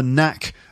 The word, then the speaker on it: knack: knack of being able to read for pleasure. So the expression here is the knack of doing something. Knack is spelt K-N-A-C-K. It's a silent K.